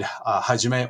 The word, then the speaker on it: Hajime: And my name, Hajime, can be read Hajime or So.